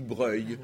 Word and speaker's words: Broglie: Broglie, ça se dit breuil, ah etc.